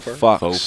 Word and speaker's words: faux: It's spelled like fox. – Yeah. F-A-U-X, that's how you spell it, though, right? – Faux, yeah. – Why they say it different? – Yeah, FAUX.